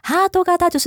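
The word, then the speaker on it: 哈 多 嘎 达 (ハート形): 那卡塔原本是念卡塔基，好，但是因为它接在哈多这个字后面，好，就念成嘎达。哈多嘎达就是爱心形状的。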